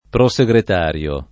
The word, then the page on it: prosegretario: lingua italiana